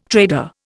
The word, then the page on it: trader: Indian